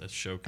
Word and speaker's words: Ahsoka: I believe it's pronounced Ashoka.